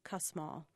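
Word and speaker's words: Kussmaul: I always coach people to say it's like swearing at the mall, cuss mall.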